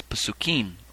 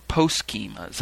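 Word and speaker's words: pesukim: The plural is Pasukim or Poskim. Sometimes it's as it's pronounced in Ashkenazic circles.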